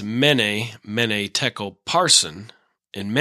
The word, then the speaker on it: tekel: Mina, and then tekel rhymes with shekel, and then parson.